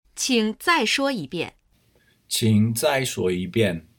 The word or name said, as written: qing zai shuo yibian